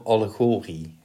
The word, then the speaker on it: allegorie: Nee, het is volgens mij eigenlijk een Grieks woord. En dan ligt volgens mij de klemtonen altijd op het ene laatste lettergreep. – Dus we gaan voor allegorie.